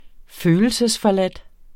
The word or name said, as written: følelsesforladt